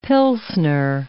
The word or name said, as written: pilsener